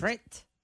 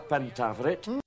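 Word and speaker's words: Pentavirate: Pentaveret. – I still don't know how to pronounce it. – Pentaveret. – Pentaveret? – The Pentaveret.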